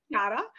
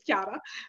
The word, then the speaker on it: Chiara: So my name's Chiara, but obviously with the Australian pronunciation, I get Chiara, which is fine.